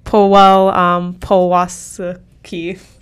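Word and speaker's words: Paweł Pawlikowski: I'm going to pronounce this wrong, so I apologize. Powell Pawlowski. – Perfect. – Yeah, thank you.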